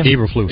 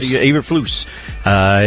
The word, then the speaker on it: Eberflus: But apparently, with general knowledge, the current head coach, how do you say, is it Eberflus? Is that how you say his name? – Eberflus. – Eberflus.